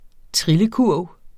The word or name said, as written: trillekurv